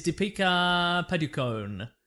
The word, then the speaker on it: Deepika Padukone: Yeah, her name is Deepika Padukone. I'm saying that incorrectly, but there it is. – Nah, you got that, mate.